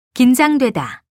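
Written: 길

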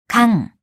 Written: 강

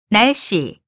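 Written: 남기다

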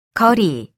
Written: 거리

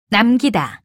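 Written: 낮

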